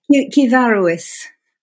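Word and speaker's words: cyfarwydd: Kivaruis. Sorry, this is the old British language, not the BBC language you're used to. It's Kivaruis, and it means storyteller.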